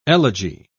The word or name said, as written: elegy